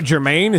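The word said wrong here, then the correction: Germán: Is that how you say his name? – Herman. – German. – Herman. – Soft G. – She makes H. – Erman.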